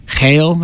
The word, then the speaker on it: Geel: We just played in some smaller towns like I think it's Geel is how you say it, but it's spelled like Geel, something like that.